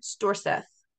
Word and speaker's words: Storseth: Belle Gunness was actually born by the name Brynhild Paulsdatter Storthus. Storseth? Didn't say that right. – I'll try it again. Belle was actually born by the name Brynhild Paulsdatter Storseth on November 22nd, 1859, in Selbu, Norway.